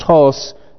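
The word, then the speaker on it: hos (ὡς): Now, the only two places in the Scripture that the one Greek word, hos, it's omega, sigma, and it's got the rough breathing mark, so it's pronounced hos.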